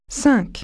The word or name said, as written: five